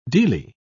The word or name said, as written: dele